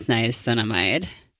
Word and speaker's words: niacinamide: I had to re-up just like my exfoliant and my niacinamide. – Yeah, okay, I'm glad you said it because I don't know how to fucking pronounce that word. – Niacinamide. I'm looking at it now and it is niacinamide.